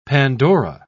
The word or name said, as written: pandora